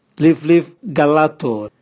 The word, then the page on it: livlivcalato: Raga